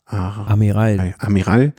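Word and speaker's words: Armirail: Bruno Armelia, wie spricht man das aus? Kannst du es aussprechen? Ich kann es nicht. – Amiral.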